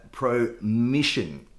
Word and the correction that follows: Promession: I think I'm probably saying that wrong. – Promission? – Promession? Promession? – Yeah. – Promession.